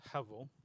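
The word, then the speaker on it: hevel (הבל): So as Brian's question just alluded to, the meaning of hevel, this Greek, this Hebrew word, and you pronounce it with a V in the Hebrew, hevel, is really, I think, key to the usage or the understanding of the book.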